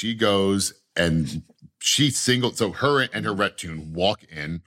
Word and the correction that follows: retinue: Retinue. Someone corrected me on Spotify. I was pronouncing that one wrong. And I still am going with retune.